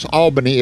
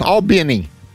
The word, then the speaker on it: Albany: I mispronounce Albany. It's Albany. Albany, Georgia. Albany.